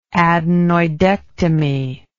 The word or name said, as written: adenoidectomy